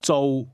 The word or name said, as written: do